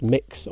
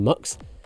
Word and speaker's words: Mx: Avi Vary prefers the one spelled MX, pronounced mix or mux.